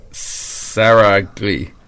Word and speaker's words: Saragli: Traditional Greek pastry, Oriental. Saragli. S-A-R-A-G-L-I. – Mm-hmm.